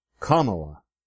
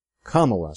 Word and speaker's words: Kamala: And just a fair warning, please make sure you pronounce Harris's first name correctly. It's Kamala. Kamala. Say it with me.